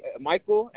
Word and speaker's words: Michael: Michael, M-I-C-H-A-E-L. – Okay, and that's spelled M-I-C-H-E-L, A-E-L? – A-E-L.